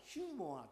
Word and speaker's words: ユーモア: でもそれらのものを吹き飛ばすのはユーモアだ。 – 但可以让这些事情能够全部消失的是你的幽默。 – 私はユーモアという日本語の文字を本当はあれヒューモアって言うんですね。